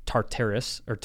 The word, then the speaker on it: Tartarus: It's Tartarus.